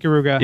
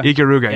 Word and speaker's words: Ikaruga: Ikaruga. – Ikaruga, yeah.